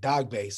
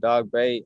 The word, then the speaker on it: Dogboe: I want to apologize to Isaac. I pronounced his name earlier, Isaac Dogboe, is I Isaac Dogbay. – So my apologies to everyone out there. – I, I people say dog bow and dog bay. I don't really. – I think the correct way to say it is dog bay. – Dog bay. – Yeah, yeah, yeah, the way you said it.